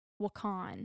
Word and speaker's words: Wakan: She like made it a point to say that they said it Wakan. – Wakan, yeah, that's how it was. – Like Wakanda.